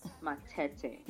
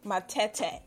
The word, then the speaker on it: Matete: just pronounce my other name so weirdly, but you're actually doing it right. It's Cathy Matete. Matete.